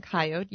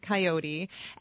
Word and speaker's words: coyote: I'm saying coyote, you're saying coyote, and the name of this animal can be pronounced either way.